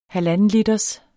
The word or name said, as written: halvandenliters